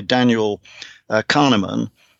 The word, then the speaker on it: Kahneman: It's called Thinking Fast and Slow by Daniel Kahneman. I think that's how you pronounce his name.